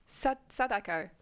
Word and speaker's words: Sadako: And then anyone who's confused, Samara is the American name for Sudoku. The numbers game. She comes out the TV. How do I say it? Sadako.